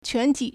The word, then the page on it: 全体: quántǐ